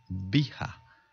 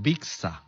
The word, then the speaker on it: Bixa: Pero en este caso, en términos taxonómicos y de acuerdo al nombre, Vija se escribe B-I-X-A, Vija. Algunas personas le dirán Vixa, pero se puede decir Vixa o Vija, Vija Orellana, y el Vixin, así se le llama a este carotenoide, o Neovixin.